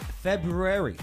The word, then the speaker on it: February: Bay, it's February. Such a tough one to pronounce. Like, I've heard of, silent S, right? I've heard of silent letters. I just never heard of the silent R.